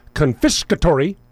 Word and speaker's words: confiscatory: Anything they can do to get away from America's confiscatory, that's four syllables or five? Five.